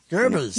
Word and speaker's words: Goebbels: But it made me think of, how do you say his name? Is it Goebbels? – Goebbels.